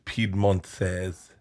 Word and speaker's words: Piedmontese: You spell it weird. You don't. It's Piedmontese.